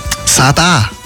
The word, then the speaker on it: Zadar: Zadar oder Zadar? – Wie sagst du es? – Zadar. – Zadar. – Zadar, gell? – Zadar. – Ja, genau. – Bist du Zadar oder noch nicht? – Es geht jedenfalls nach Kroatien.